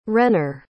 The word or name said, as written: renner